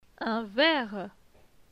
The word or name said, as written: Un verre